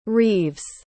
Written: reeves

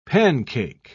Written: pancake

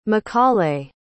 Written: mcaulay

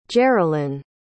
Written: geralynn

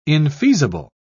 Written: infeasible

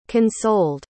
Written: consoled

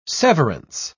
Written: severance

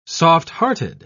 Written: softhearted

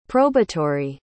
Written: probatory

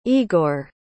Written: egor

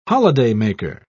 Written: holidaymaker

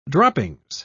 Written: droppings

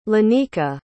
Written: lynika

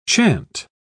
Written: chant

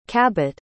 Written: Cabot

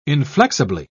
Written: inflexibly

